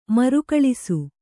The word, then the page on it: ಮರುಕಳಿಸು: marukaḷisu